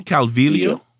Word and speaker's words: Calvillo: Calvillo? Is it Vilio? – Mm-hmm. – Cynthia Calvillo.